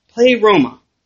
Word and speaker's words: pleroma: Now, fullness in the Greek is pleroma. I think that's right. Pleroma. Forgive my Greek pronunciation.